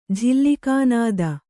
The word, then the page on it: ಝಿಲ್ಲಿಕಾನಾದ: Kannada